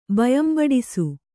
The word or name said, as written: ಬಯಂಬಡಿಸು